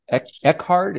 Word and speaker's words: Eckhart: Now, I see you've dedicated the album to Shirley Eckhart.